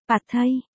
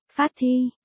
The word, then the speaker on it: pad thai: Tiếng Thái đọc là, pad thai, phát thi, và cả hai món đều cay, em thích ăn cay, đồ ăn Thái cay cỡ ăn phô em cũng nuốt được hết.